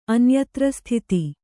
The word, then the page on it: ಅನ್ಯತ್ರಸ್ಥಿತಿ: anyatrasthiti